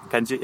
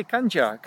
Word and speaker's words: Kanji: Kanji, yeah. – Kanji, now I said it right. What did I say? Kanja? Kanja? – Kanja, I don't know.